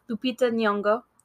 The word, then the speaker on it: Lupita Nyong'o: Lupita Nyong'o.